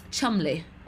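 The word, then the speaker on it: Cholmondeley: It's pronounced Chumley, okay? Because that's what aristocrats do.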